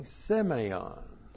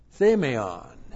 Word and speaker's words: Semeion: Semeion. Looks like that in the Greek. S long E-M-E-I-O-N. Semeion.